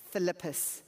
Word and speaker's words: thlipsis: I'll spell it. T-H-L-I-P. S-I-S, Philippus.